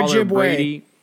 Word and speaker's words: Ojibwe: How do you say it again? – Ojibwa scholar Ojibwe.